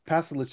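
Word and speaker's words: Pašalić: Palisic? Pasalic? Pasalic? How do you say his last name? – Yeah, Pasalic sounds right.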